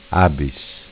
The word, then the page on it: Abis: Dialetto